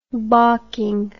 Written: bocking

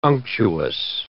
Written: unctuous

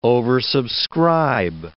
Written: oversubscribe

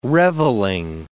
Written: revelling

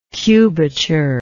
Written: cubature

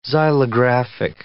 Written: xylographic

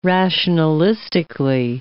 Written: rationalistically

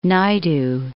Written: Naidu

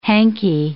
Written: hankie

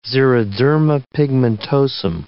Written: xeroderma pigmentosum

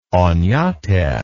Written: onate

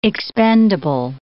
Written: expendable

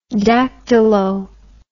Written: dactylo

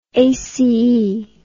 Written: aceae

